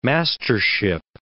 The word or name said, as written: mastership